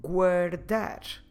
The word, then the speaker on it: guardar: For instance, the verb guardar.